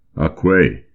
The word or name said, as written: Aquay!